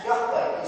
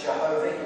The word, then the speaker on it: Yahweh: Now, Yahweh is the personal name of God. It only has consonants, so what vowels you put in there is up to you. And so it's sometimes pronounced as Jehovah.